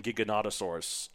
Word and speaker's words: Giganotosaurus: Like, I saw, speaking of dinosaurs, someone posted on Twitter, like, how do you pronounce the G in Giganotosaurus? I always say Giganotosaurus or Giganotosaurus, but like, Jurassic World Dominion, there was a Giganotosaurus, and it said Giganotosaurus. Mm. But I always said Giganotosaurus because it's like gigantic, whatever. Mm. One of the comments said, it's pronounced like the G in GIF, which I thought was very funny.